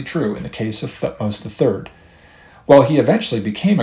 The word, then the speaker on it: Thutmose: I speak of Thutmose III, and Thutmose is spelled T-H-U-T-M-O-S-E, Thutmose.